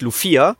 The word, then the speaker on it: Lufia: Und ich habe es damals immer auch noch Lucia ausgesprochen und nicht Lufia. Und Lufia, beziehungsweise Lufia 2 ist es ja eigentlich, Rise of the Sinistrals, aber der erste Teil erschien bei uns nie, deswegen heißt es ja hier Lufia.